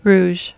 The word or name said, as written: rouge